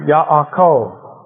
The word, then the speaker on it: Ya'akov: But both A's are pronounced Ya'akov, And the stress is at the end if you want to say it right.